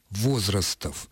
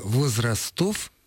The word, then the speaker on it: возрастов: Возраст, возраста, возрасты, возрастов. Следовательно, ударение возрастов – это вариант разговорный. То, что он иногда звучит и по радио, ничего не меняет. правильный, строго литературный вариант предполагает сохранение ударения там же, где оно в начальной форме, именно для этого слова.